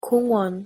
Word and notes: 공원: Korean

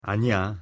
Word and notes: 아니야: Korean

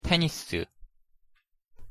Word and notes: 테니스: Korean

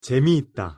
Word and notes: 재미있다: Korean

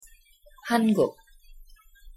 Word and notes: 한국: Korean

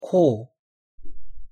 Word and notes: 코: Korean

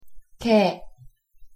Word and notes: 개: Korean

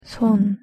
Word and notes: 손: Korean

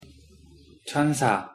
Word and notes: 천사: Korean

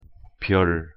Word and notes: 별: Korean

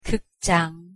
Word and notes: 극장: Korean

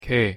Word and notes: 게: Korean

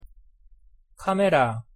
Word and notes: 카메라: Korean

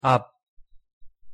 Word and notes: 앞: Korean

